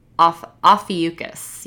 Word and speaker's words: Ophiuchus: And in the spirit of Scorpio season, since it follows Scorpio, it's actually called Ophiuchus.